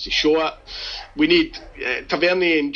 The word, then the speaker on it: Tavernier: He said your pronunciation of Tavernier drives him nuts. – Okay. – It's Tavernier. – It's Tavernier. – Tavernier, it's a French word.